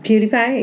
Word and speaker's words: PewDiePie: And the first guy says, PewDiePie. Is that how you say it? – PewDiePie, yeah.